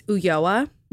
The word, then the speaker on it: Ulloa: Everyone does. It's Ulloa. – That's what I thought. – Yeah. – That's what I was assuming, but I just felt like I didn't want to just jump into that. And not say it wrong. – I'm glad you asked. In Spanish, a double L is like a Y.